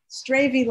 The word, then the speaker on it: Stravie: Stravie like gravy. I remember you told me. – Yeah, it's spelled different than gravy, but it sounds the same.